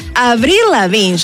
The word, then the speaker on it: Avril Lavigne: Avril Lavigne, yo lo dije bien, mi querida Sophie.